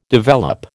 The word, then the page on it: develop: American English